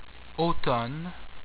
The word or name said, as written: Automne